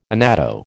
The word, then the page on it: annatto: American English